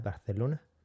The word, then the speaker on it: Barcelona: And how the locals say Barcelona. – Barcelona, la Barceloneta.